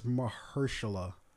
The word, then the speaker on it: Mahershala: I believe it's Mahershala. I'm probably getting nervous. No, you're right. It's M-A-H-E-R. So Mahershala.